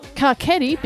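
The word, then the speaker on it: Carcetti: Carchetti, because that's how you say his name, apparently, is running for mayor.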